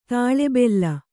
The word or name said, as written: ತಲೆಬೆಲೆ